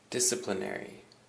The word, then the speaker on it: disciplinary: And now we have two more words, so our second last word is disciplinary. Disciplinary. And the emphasis is on the first syllable, the dis.